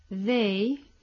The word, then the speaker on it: they: Ahí tenemos TH que se pronuncian Z, mientras que en they la pronunciación es más parecida a una D española.